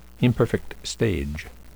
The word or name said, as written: imperfect stage